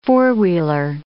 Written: four-wheeler

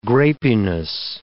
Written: grapiness

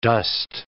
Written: dost

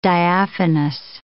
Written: diaphanous

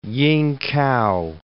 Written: Yingkow

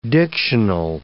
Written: dictional

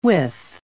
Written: withe

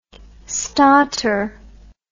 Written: stotter

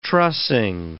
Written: trussing

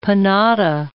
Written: panada

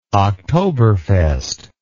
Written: Oktoberfest

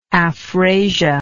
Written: afrasia

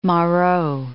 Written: Moreau

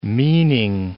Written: meaning

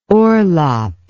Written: orlop

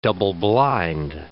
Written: double-blind

